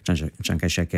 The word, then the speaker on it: Chiang Kai-shek: perché per riuscire a stanarlo Chiang Kai-shek, Chiang Jie-shi in cinese, Chiang Kai-shek è la pronuncia a cui noi siamo abituati, quella cantonese, quella meridionale,